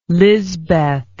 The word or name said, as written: Lizbeth